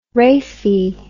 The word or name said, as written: rhaphe